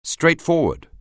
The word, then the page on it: straightforward: anglais